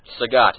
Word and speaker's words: Sagat: Sagat. – Sagat, excellent. – Not of course, Bob Saget.